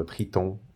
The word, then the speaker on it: Triton: So Triton uh, being the French um, uh, pronunciation, obviously.